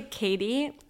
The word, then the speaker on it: Katie: So actually, when I say Katie in Australia, they think I'm saying Kitty, and so I actually have to say my name in their accent. Funny. Even if I speak pure Canadian, then I stop with my name because I'm like, I'm not Kitty.